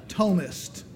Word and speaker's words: Thomist: Thomist. That's how, T-H-O-M-I-S-T. The H is silent.